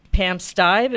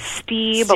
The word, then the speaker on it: Stibe: So your mom, Pam Stibe, is that how she pronounces her name? – That's amazing. You're the first one that I think has ever got it right on the first time. – Really? What's so complicated about that? – I don't know if it's a vowel thing. A lot of people say Stieb, a lot of people say Stein. Mm-hmm. But yeah, they often mispronounce her last name.